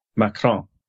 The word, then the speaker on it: Macron: Macron.